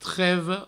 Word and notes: treva: trevo

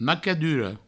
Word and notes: macadura: Provençal; macaduro

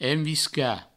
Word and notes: enviscar: envisca; provençal

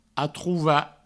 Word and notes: atrovar: atrouva

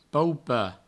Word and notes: paupar: paupa; provencal

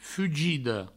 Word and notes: fugida: fugido

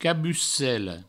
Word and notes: cabucela: cabucello

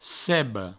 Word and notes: ceba: provençal; cebo

